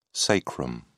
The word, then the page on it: sacrum: British English